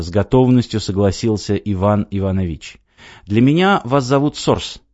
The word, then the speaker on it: Иванович: Причем Иванович — фамилия. Ударение на последнем слоге. Это важно».